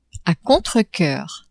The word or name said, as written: a contrecoeur